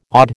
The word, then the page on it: odd: American English